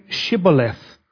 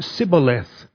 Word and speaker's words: Shibboleth: When he said, "No," they said to him, "Then say Shibboleth." And he said Sibboleth, because he could not pronounce it right.